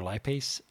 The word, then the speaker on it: lipase: So typical person, their lipase number is around, am I saying that right, lipase? – Mm-hmm.